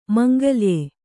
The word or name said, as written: ಮಾಂಗಳ್ಯ